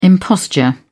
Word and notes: imposture: British English

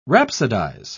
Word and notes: rhapsodise: British English

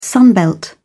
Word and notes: sunbelt: British English

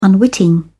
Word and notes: unwitting: British English

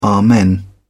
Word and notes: amen: British English

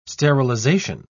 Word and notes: sterilisation: British English

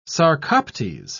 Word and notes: sarcoptes: British English

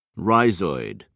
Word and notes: rhizoid: British English

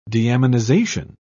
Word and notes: deaminization: British English

